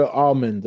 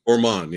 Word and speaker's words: Ormond: Julia Ormond, okay. – Ormond, yeah. – Ormond, yeah, I said it wrong.